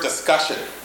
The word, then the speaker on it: discussion: Now, a word about the word discussion. Discussion rhymes with percussion.